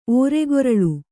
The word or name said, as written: ಓರೆಗೊರಳು